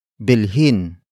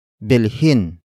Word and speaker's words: bilhin: Bilhin mo ang alahas. bilhin mo ang alahas. The first word is bilhin. Bilhin. Bilhin. Bilhin is spelled as B I L H I N.